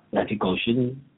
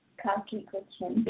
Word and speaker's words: Kalki Koechlin: Kalki Koechlin. Say it like you mean it. – Kalki Koechlin.